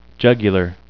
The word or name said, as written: jugular